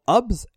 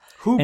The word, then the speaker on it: HOOBS: Hoobs, H-O-O-B-S. – Hoobs.